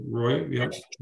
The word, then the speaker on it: Rouy: R-O-U-Y. – Mm-hmm. – Roy, maybe? I don't know. – R-O-U-Y, Roy, yeah.